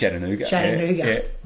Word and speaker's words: Chattanooga: And Chattanooga, is that how it's pronounced? – Chattanooga.